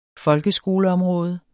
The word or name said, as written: folkeskoleområde